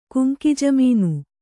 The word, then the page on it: ಗಂಗಾಜಮುನ: Gaŋgā jamuna